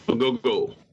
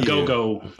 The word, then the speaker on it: Ogogo: Ogogo. – Thank you. – Ogogo.